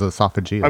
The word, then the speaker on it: esophageal: No, that's actually how you pronounce it, is esophageal.